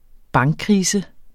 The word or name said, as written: bankkrise